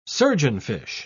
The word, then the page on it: surgeonfish: British English